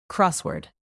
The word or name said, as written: crossword